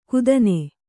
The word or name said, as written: ಕುದನೆ